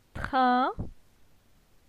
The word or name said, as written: Trin